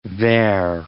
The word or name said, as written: vair